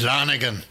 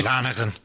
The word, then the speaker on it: Lonergan: And it's Lonergan. yeah, that's right.